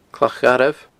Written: clachgharbh

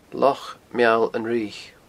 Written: Loch Meall an Fhraoich

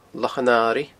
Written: Lochan Airigh

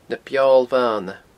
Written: Na Beoil Bhana

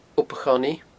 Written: Ob a' Chonnaidh

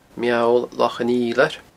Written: Meall Loch an Fhidhleir

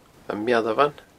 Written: ammealbhan

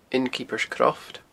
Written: Innkeepers Croft

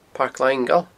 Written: Pairc Langail

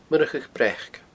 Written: Murchadh Breac

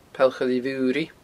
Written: Poll Choille Mhuiridh

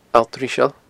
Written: alltriseail

